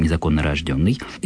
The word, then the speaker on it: незаконнорожденный: Подчеркну именно с таким правильным ударением, незаконнорожденный.